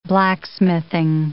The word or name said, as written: blacksmithing